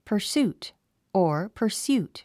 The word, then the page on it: pursuit: pə r súːt; パァスート